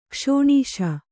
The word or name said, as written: ಕ್ಷಣಶಃ